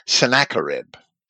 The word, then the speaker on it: Sennacherib: And let me say that Isaiah lived and continued to write for at least several years after the siege of Jerusalem by Sennacherib, which is commonly dated to 701 BC. Some people pronounce Sennacherib as Sennacherib.